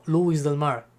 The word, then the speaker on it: Lewis del Mar: Luis del Mar. Se escribe Lewis del Mar.